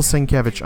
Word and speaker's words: Sienkiewicz: And the art cover of the week was Daredevil, or the art cover winner, Daredevil number 6 by Bill Sienkiewicz. Sorry if I pronounced that incorrectly. Sounds like about right. Sienkiewicz. Sienkiewicz.